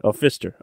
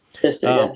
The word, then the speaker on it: Pfisterer: Pfisterer. – Okay. – Pfisterer, yeah.